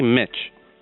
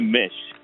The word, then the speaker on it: Misch: How do you pronounce M-I-S-C-H? – Yeah, that's a great question. I say Mitch. – I say Mish. – And some people say Mish, but it sounds awkward. Mish, like mishmash, mash, yeah.